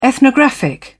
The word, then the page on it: ethnographic: British English